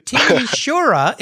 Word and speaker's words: Timișoara: Originally from, and I'm going to butcher this one too, Timișoara in Romania, right? – That was good.